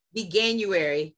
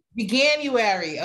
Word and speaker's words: Veganuary: Four years ago, Angela participated in veganary. I can't say that. Vegan veganary. How do you say it? Veganuary. Veganuary. Okay.